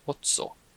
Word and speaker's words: otso: Actually, it's ocho, but in Spanish, that would be ocho. But in Cebuano or even in Filipino, we say ocho, ocho. That would be A, or sorry, it's spelled O-T-S-O, ocho.